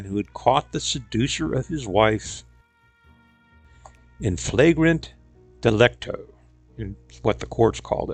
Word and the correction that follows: in flagrante delicto: The correct pronunciation is in flagrante delicto, meaning caught in the very act of committing a misdeed.